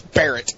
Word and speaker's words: Barrett: You uncultured fuck, it's Barrett. Like the sniper rifle.